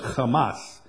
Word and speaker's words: Hamas: It's pronounced, well, it's got the rough breathing mark on it, so it's Hamas.